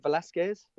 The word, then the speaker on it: Velasquez: Cain Velasquez, is that right? – Why not? – Did I say that right? – Yeah, that's fine. – Okay, and Brock Lesnar.